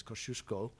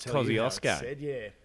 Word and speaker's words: Kosciuszko: Kosciuszko National Park, which we established on the drive here, probably would best be pronounced as Kosciuszko. – Kosciuszko. – As a Polish thing, but as Australians, we don't care for the traditional pronunciations. – No, mate, Kosciuszko.